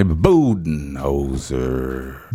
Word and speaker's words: Budenholzer: Budenholzer.